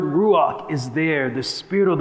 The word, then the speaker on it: ruach: There's this Hebrew word. It's pronounced ruach.